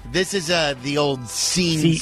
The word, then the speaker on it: Sean: Went to college with a guy in Boston whose name was spelled S-E-A-N. But it was pronounced Scene.